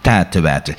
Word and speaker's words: tijtewijte: Vandaag het woord, hopelijk zeg ik het goed, tijtewijte. – Je zegt het absoluut goed. Tijtewijte. In Zuidwest-Vlaanderen zou men zeggen tijtewijte. Zoals men daar zegt paard, zeggen wij paard, wel nu ook tijtewijte.